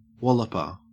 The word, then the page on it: walloper: Australian English